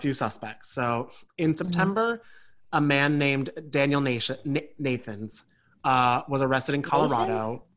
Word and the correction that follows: Nations: I think it's Nations. – Yeah. – Spelled like Nations.